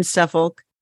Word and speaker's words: Suffolk: And for those who don't know, it's a large village in northern Suffolk. – It's Suffolk. – Halfway between Suffolk. – There's no L.